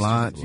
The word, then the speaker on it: Solange: Solange? Is that how you say her name? Solange? How do you say the sister? Solange, yeah.